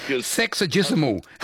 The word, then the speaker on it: sexagesimal: Sexagesimal.